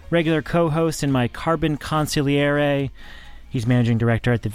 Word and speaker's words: consigliere: I want to say consigliere, but consigliere is the right way to pronounce it, I guess. – Sure. As someone who's definitely fluent in Italian, I can tell you that is, you got it perfect.